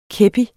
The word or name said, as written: kepi